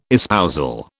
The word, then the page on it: espousal: British English